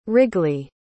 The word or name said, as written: wrigley